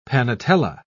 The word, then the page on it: panetela: British English